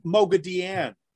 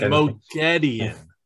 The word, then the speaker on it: Moghedien: No, it's Moghedien. Moghedien. – That's what I said. – No, it's Moghedien. Not. No, it's not. You so put the wrong emphasis on the wrong syllable.